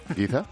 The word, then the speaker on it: Giza: Al sur de las famosas pirámides de Giza, ¿se dice así? – Sí. – Giza, en Egipto, se han encontrado restos del templo de Ramsés II.